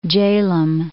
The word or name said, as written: Jhelum